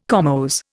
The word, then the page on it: comose: Indian